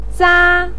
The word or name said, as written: za1